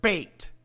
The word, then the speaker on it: Beth: B-E-T-H spells Beth in English, but it's pronounced bait, like fishing bait in Hebrew.